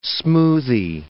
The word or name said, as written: smoothie